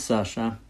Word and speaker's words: Sasha: My name is actually written Alexander, but it's pronounced Sasha, because Sasha is nickname for Alexander, where I come from, and they speak like this in this very large and aggressive country.